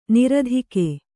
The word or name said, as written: ನೀರ್ತೆಗೆ